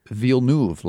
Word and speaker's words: Villeneuve: Chris Fivey said, pretty sure it's Villeneuve, lads. He spells it V-E-E-L-N-O-O-V. So a vote for Villeneuve. – The long E in that one. Dakota Arsenault, also on Twitter, says, this is from a Canuck, Denise. Ville like the village, noof. And then she has rhymes with hoof. So she spells it N-U-U-V.